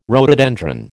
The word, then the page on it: rhododendron: American English